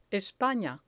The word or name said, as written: espana